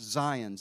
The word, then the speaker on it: Sion: That's S-I-O-N, but it's pronounced Zion.